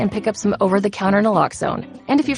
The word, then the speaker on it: Naloxone: For the record, it's Naloxone. Nah, like, is Naloxone addictive? Nuh-uh. Lox like Naloxone keeps humans safe like locks keep bikes safe, and own like Naloxone can save lives in our very own communities.